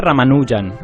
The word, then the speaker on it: Ramanujan: Todo el mundo dice Ramanujan, con acento en la U.